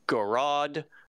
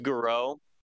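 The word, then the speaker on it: Gouraud: I've seen people say Garod, like pronouncing the D, but I don't think the D is supposed to be pronounced. So I'm going to go with Garou.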